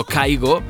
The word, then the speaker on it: Kygo: Después tenemos a ese productor llamado Kygo, aunque se escribe con K-Y-G-O, junto a Khalid y Griffin, un temazo llamado Save My Love.